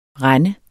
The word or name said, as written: rend